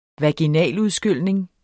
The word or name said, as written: vaginaludskylning